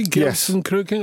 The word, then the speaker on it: Gelsenkirchen: Did I pronounce it correctly? Gelsenkirchen? – Yes.